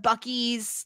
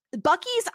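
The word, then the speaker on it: Buc-ee's: Have you ever heard of Bucky's, like, in the United States? It's a convenience store chain. – No. – It's spelled differently. It's spelled Bucees. Bucky's, I've never been to one because they only really exist in the South.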